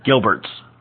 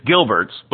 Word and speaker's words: Jilbert: Gilberts, with a J. – Gilbert. – Yeah, with a J. J-I-L. – Gilbert. – Yep. No, it's Gilberts, but we spell it with a J.